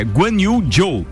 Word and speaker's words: Guanyu Zhou: Só, só, atenção, desculpa. É Guanyu Zhou.